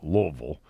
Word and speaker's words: Louisville: Denny Crum built Louisville, and it is pronounced Louisville, into a powerhouse in college basketball.